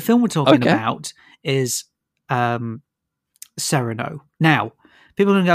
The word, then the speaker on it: Cyrano: Now, just to address the Cyrano thing, in this film, it is pronounced Cyrano.